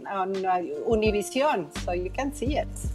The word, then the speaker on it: Univision: And this one, Univision. – Univision. Yeah, see, when you said it that way. – Uno, like number one.